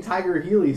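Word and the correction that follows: Tiger Heli: since I was 5 years old. – Helicopter. – Oh, it's Tiger Helly. Man, I really thought it was Tiger Healy all these years.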